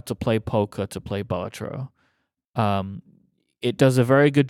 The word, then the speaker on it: Balatro: But I put it down and I've immediately picked up Balatro, which is how I keep saying it. Federico, how should I say it? – Balatro. Yeah.